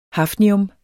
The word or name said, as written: hafnium